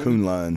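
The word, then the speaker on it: K'un-Lun: Favorite thing about this Iron Fist show, the only thing I was very happy with is I never knew how to pronounce Kun Lun. I didn't know if it was Kun Lun or Kun Lun. – Kun Lun. Because there's a little apostrophe in there and everything.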